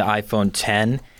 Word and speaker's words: iPhone X: The big thing with the iPhone X, which they're pronouncing the iPhone 10, is that there's no home button.